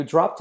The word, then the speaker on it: dropped: You dropped it. You dropped it. Y la T final casi no se dice.